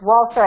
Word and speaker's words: Walser: It's Walter, and Walter is WALSER. It's like wall and then sir, like SIR, but it's spelled SER.